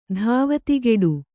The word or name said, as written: ಧಾವತಿಗೆಡು